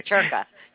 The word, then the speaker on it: circa: In Italian, it's pronounced circa. But nobody here pronounces it that way, and it makes me crazy. In Italian, C-I is pronounced ch.